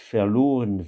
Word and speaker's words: verloren: Nicht verloren werden, ce qu'il faut remarquer ici, c'est de nouveau le fait que le V de verloren se prononce F, comme dans Francis.